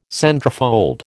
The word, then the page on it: centrefold: American English